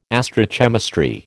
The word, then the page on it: astrochemistry: American English